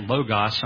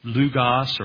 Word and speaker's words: logos: And I will hear people say the word logos. I'll say it logos. Some will say lugos, or they'll use different pronunciations of the same word.